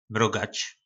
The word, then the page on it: Mrogac: po nasimu